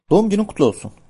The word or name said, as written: olsun